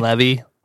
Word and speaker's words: Levy: But that Seth Green plays Jimmy Bender, and then Eugene Levy, how do you pronounce his name? – Eugene Levy. – It's L-E-V-Y, so whatever.